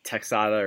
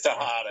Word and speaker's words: Texada: I know Ranthony Texada had some good plays in Texada or Tejada. I don't know. I'm just going to say it how it's spelled. – It is Tejada. – It's Tejada. Okay.